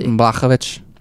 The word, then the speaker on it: Blachowicz: Yeah, and you pronounced the name so well, it's crazy. – Blachowicz.